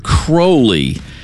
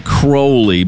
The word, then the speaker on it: Crowley: Now, Aleister Crowley pronounced his last name Crowley, but in history, we all say Crowley. It just rolls off the tongue a little bit better.